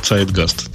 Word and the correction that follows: Zeitgeist: да? Зидге. Он, по-моему, какой-то немецком надо говорить, да? Это немецкое, это немецкое слово, оно означает дух во имени, и поэтому, ага. соответственно, произносится Zeitgeist.